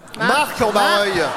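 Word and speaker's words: Marcq-en-Barœul: À Mar en Barreul. – Marc en Barreul. – Marc, putain!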